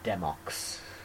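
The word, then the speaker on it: Demoux: That's how I pronounced it too, because it was like Renault, but yeah, it's got an X on the end, so I think it's silent. – Demox. – That's the other way you can pronounce it.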